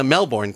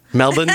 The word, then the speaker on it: Melbourne: Melbourne, correct? – Correct. – All right. It's just wonderful. – Although we pronounce it Melbourne. – Melbourne? Okay. Well, I apologize.